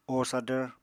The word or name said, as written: o sadvr